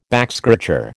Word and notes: backscratcher: American English

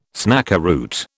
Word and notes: snakeroot: British English

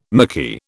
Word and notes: micky: British English

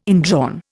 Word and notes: indrawn: Indian

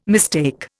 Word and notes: mistake: Indian

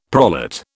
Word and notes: prolate: British English

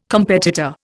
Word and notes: competitor: Indian

